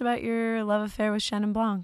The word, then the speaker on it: Chenin: Chenin. It's not Chenon. – No. – How do you say it? – Yeah. – I just call it Chenin. – C-H-E-N-I-N.